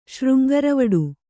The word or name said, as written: ಶೃಂಗರವಡು